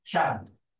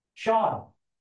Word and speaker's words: Sean: Okay, for example, if I spell out a name, S-E-A-N, how would you pronounce it? Right. Shan. Okay, anyone else? Sean. Thank you. It's Sean. All right. It's Irish. It's another name for John. Sean. Shan is actually the female version. S-I-A-N.